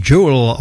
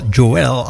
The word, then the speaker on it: Joel: The Rivers of Dream van Billy Joel. Oftewel Joel, als je het in het Nederlands wilt uitspreken.